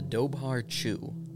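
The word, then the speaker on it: Dobhar-chú: The Dobhar Chew. Irish pronunciation that.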